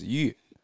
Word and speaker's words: Yu: So my first name in Chinese is Yu.